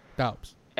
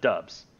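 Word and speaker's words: Doubs: And I called him Romeo Dobbs for the longest time, and then everybody told me, no, it's pronounced Dubs.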